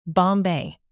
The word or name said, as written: bombay